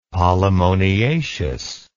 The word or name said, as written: polemoniaceous